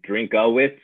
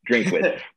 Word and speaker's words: Drinkwitz: A lot of announcers say Drinkowitz, and I don't like that. Drinkwitz. just the two syllables. There's no A in it.